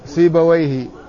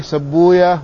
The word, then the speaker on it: سيبويه: قوله ذكرها سيبويه هكذا طبعا يقولها اللغويون، يقولون سيبويه، وأما المحدثون فإنهم يقولون سبويه ولا ينطقون بويه، يقولون لأن ويه اسم من أسماء الشياطين وهم لا يتعاملون مع الشياطين، ولذلك يقولون في راهويه راهويا، وفي نفطويه نفطويا، وفي سيبويه سبويا، وهكذا.